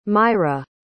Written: Myra

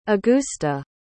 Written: agusta

